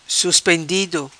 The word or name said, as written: Suspendido